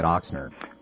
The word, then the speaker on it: Ochsner: Well, you're pronouncing it the way that they say is correct. But I grew up in New Orleans, and I grew up saying Ochsner instead of Ochsner, and it I have to say it the way I'm comfortable with. I think there are two pronunciations. If you went to New Orleans and asked 100 people, you'd probably hear 50 each way.